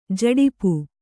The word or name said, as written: ಝಡಪ